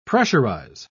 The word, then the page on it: pressurise: British English